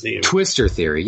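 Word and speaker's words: twistor: One of those you mentioned was that alternative to string theory, which is, I believe, spinner theory or spinner theory. I'm forgetting how you pronounce it. – Twister theory.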